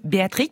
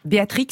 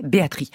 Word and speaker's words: Béatrix: Le papa belge lui choisit son prénom, qui n'est ni Béatrice ni Béatrix. Enfin si, ça s'écrit de la sorte, Béatrix, mais lui voulait qu'on prononce Béatrix comme perdrix. Donc on va l'appeler comme il voulait, Béatrix.